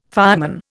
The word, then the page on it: fireman: Indian